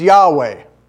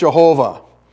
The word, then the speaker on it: Yahweh: So given this clue, most theologians would then say the formal name for God is Yahweh. And that would be the correct pronunciation of it. Now, some of you heard that God's name is pronounced Jehovah.